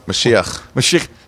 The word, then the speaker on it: Mashiach: But it's Squirrel Hill is this incredibly Jewish region of Pittsburgh where they have billboards that say Moshiach is coming. – Mashiach. – Hey, man. – Mashiach is coming.